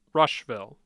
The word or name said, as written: Rushville